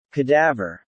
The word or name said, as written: cadaver